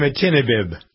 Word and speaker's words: imatinib: And so, but the thing that's really impressive, so this, you know, the leukemia drug that's sort of been talked about, I can't even pronounce it. It's LMATINIB. It's the worst name ever. But it's a really important drug, and it's just under $9,700 a month. – Imatinib.